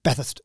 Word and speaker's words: Bathurst: I don't think we really pronounce the U and the R, just Bathurst.